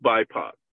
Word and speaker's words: BIPOC: It says it stands for Black, Indigenous, and People of Color, pronounced BIPOC.